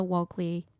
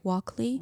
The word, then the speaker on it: Walkley: Walkley? – Walkley Award. – Yeah, Walkley. No, see, it sounds dumb when I say it like that.